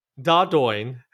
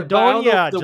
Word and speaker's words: Dordogne: Dordogne. It's Dordogne.